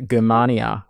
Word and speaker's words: Germania: The text that we're talking about is the Germania with a hard G.